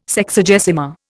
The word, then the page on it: Sexagesima: Indian